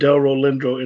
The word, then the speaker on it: Delroy Lindo: Delroy Lindo.